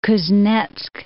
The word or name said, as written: Kuznetsk